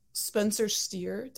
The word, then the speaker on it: Spencer Steer: But probably Spencer Steer.